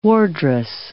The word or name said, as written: wardress